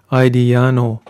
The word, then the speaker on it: Ajdijano: So it sounds like you pronounced it Aidiano.